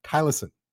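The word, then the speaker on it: thylacine: I hope I pronounced the correct name of the tiger correctly. Tylosin. – Yeah, you did.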